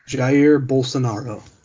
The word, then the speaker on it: Bolsonaro: which I don't know if I pronounce his name correctly, but it's Jair Bolsonaro. – Bolsonaro. Say it for me. – Jair Bolsonaro.